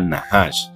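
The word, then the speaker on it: Nahash (נחש): Nahash se copia Nachas, N-A-C-H-A-S, pero la letra C no se, no se pronuncia. En hebreo se pronunciaría Nahash.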